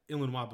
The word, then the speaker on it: Illinois: I was like, yeah, Illinois, but we pronounce it Illinois. So these guys, because they were French. So Illinois is like a French word, I guess. And they were pronouncing it the French way, Illinois. And when I told them that we call it Illinois, they were making fun of me like, Illinois, Illinois.